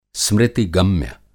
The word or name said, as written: स्मृति-गम्य